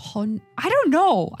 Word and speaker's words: Honor: It's written like Honor, like H-O-N-O-R, but it's pronounced as Honor.